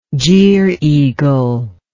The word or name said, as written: gier eagle